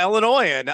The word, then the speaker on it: Illinois: Illinois? No, dude, the S is silent.